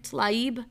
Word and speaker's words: Tlaib: Tlaib? – Yeah.